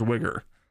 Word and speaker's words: Uyghur: I believe it's actually pronounced Wigger. I think.